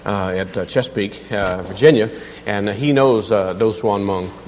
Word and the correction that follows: Moong: Moong, I'm sorry. Moong is the way you pronounce it. M-O-O-N-G is the way it's pronounced.